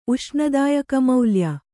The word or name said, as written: ಉಷ್ಣದಾಯಕಮೌಲ್ಯ